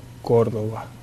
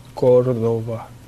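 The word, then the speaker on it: Córdova: Sergio Córdoba. Córdoba.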